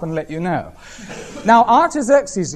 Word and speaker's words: Artaxerxes: A-R-T-A-X-E-R-X-E-S, Artaxerxes Longimanus, L-O-N-G, I-M-A-N-U-S.